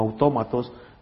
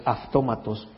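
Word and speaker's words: aftómatos (αὐτόματος): Y aunque cuando la transliteras al castellano suena como autómatos, pero la pronunciación en el griego es aftómatos.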